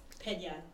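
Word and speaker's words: Peña: Pena. It's Pena. And so, like, my last name has the tilde over mm-hmm. the end, and so people see that and it freaks them out.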